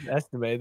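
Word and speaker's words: Estimé: And Audric, what he was doing, is it Audric? I don't know how to say his name, honestly. Just Estime. – Estime. There you go.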